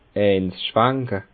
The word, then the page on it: eins schwanke: Bas Rhin; Schiltigheim